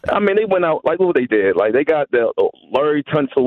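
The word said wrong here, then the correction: Laremy Tunsil: I hope I'm pronouncing his name. – Right? – Laramie Tunsil. – Tunsil.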